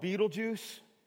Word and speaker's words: Betelgeuse: And there's one star called Betelgeuse. Yeah, it is. That's how you pronounce it.